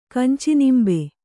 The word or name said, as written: ಕಂಚಿನಿಂಬೆ